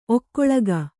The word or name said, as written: ಒಕ್ಕೊಳಗ